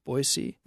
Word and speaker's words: Boise: So it, you know, but hey, the locals say Boise, so there you go.